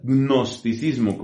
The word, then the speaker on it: gnosticismo: Un segundo error que tiene que ver con el dogma es el gnosticismo que se escribe con una G inicial, gnosticismo, como si uno dijera.